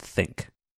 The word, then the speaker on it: ThinQ: It's you're right, where it's spelled out thin, a capital Q, but it's supposed to be pronounced think.